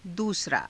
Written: duusaraa

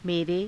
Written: mere

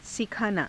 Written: sikhaanaa

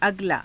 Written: agalaa

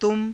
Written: tuma

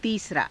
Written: tiisaraa

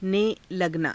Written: ने लगना

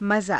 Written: mazaa

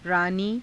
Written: raanii

